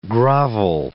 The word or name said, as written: grovel